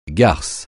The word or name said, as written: garce